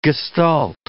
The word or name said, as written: gestalt